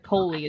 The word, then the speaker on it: Coley: I think I've been pronouncing it Coley in my head, but Coley is probably right. – Coley, because like Nicole. – Yeah. – Right. – Yeah, that makes sense, I guess. – Yeah. So Coley is getting dropped off at the train station.